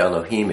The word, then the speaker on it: Elohim: Aleph, you can be pronounced Ah, E, and A. Lamed is pronounced L, the L. He is, the breath. Yod is pronounced E, and Mem is pronounced with an M. So, I mean, if you look at this, Aleph, Lamed, He, Yod, Mem. Al-ha-im. Elohim. Now, when you see just L, E-L, or sometimes L-E, E-L-I, then it becomes an A.